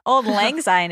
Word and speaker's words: Auld Lang Syne: Actually, did I say it wrong? – No. – I always say Auld Lang Syne. – No, Auld Lang Syne is the right way, and you did it.